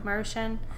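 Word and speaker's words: Maruchan: How do you say that? – Mar- Marushan. – Marushan.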